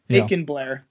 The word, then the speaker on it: Macon Blair: But this was a movie by a young director, hasn't done much, starring people you haven't heard of, including Mason Blair, I believe his name is, who is the... – Macon Blair is his name. – Macon Blair, that's how you pronounce it, who's the lead of this.